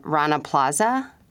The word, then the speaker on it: Rana Plaza: Rana Plaza.